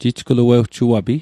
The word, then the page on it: jijgluewjuapi: Mi'gmaq